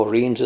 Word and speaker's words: Reims: And will you tell our listeners how Reims is pronounced in French, please? Reims.